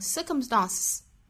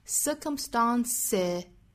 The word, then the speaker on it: circumstances: 它如果要变复数的话直接加的话就会读成 circumstances 相当于两个辅音连缀在一起了这个时候其实是为了发音方便哈，我们在两个 s 之间去加一个元音 circumstances